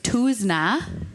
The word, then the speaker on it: Tousignant: I don't, but I had to learn to pronounce my last name in something other than Midwestern. Tousignant was Tuzna in Minnesota growing up.